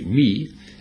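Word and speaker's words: me (μή): Modern Greeks would say me, M-E, long E, me.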